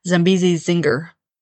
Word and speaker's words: Zambezi Zinger: Oh, and Zambezi Zingler. I don't think I said that right. Zambezi Zinger. So yeah, a detailed look at those rides.